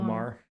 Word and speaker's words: Maher: On a recent episode of Real Time, Bill Maher, is that how you say it? – Yeah, Bill Maher.